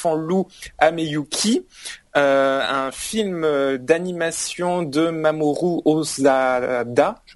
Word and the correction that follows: Hosoda: Hosoda. Hosoda. Au soda, au soda. Oui, euh, c'est de l'eau. Le, euh, H est, de l'eau et le, H est sonore, donc c'est Hosoda.